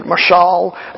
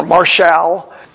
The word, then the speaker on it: mashal: Some would pronounce it mashal or marshal.